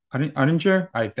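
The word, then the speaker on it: Oettinger: I believe you picked Ottinger. That's how you pronounce his name.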